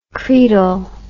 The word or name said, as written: credal